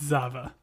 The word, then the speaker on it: Zava: But this is interrupted with some news about, is it Java? Zava? How do we say this gentleman's name? – It's Zava.